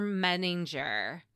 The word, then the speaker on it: Menninger: Lastly, dear Dr. Menninger. – Very good. It's the first time you've said his name right.